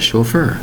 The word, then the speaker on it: chauffeur: It's called a chauffeur. Spelled S-C-H-A-U-F-F-E-U-R. Two syllables for the word chauffeur. Spelled C-H-A-U-F-F-E-U-R.